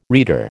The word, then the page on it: reader: American English